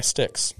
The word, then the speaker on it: Styx: It's a song by that, I think you pronounce it, by Styx.